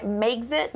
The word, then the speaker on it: Megxit: All right, more on the Megxit fallout. Is that how you say it? Megxit? – Megxit.